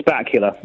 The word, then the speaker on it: Bakula: Is it Scott Bakula? Scott Bakula? How do you say his name? – It's Bakula. – Yes. – Dracula.